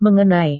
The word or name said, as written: mengenai